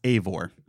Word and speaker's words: Eivor: Okay, so it's pronounced Eivor. – Eivor.